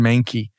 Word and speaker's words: Mahnke: So speaking of Cabinet of Curiosities, which is, again, Amanda mentioned Aaron Mankey. That's the host and the creator. And that Mankey is spelled M-A-H-N-K-E.